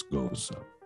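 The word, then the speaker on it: Gose: I know it looks like it's spelled ghost. It's not. It's Goza.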